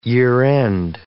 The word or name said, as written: year-end